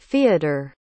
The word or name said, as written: feodor